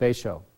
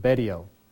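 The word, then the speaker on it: Betio: And in the bottom left corner is this little tiny island called Baisho or Baidio. People pronounce it differently.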